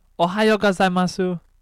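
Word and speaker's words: Ohayou gozaimasu (おはようございます): You don't pronounce the U, you dumbass. – Yes, you do. I've watched anime. You do. – Continue. – Soap, am I saying it right? – Say it again. – Ohayou gozaimasu. – Yeah, that's pretty much right.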